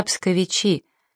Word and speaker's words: псковичи: Но сами жители славного города называют себя псковичи с ударением на последний слог.